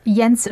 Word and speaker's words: Jens: I believe the German pronunciation is for NATO Secretary General Jans. Is that... Oh, you're talking about Jens Stoltenberg? – Oh, okay.